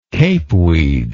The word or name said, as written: capeweed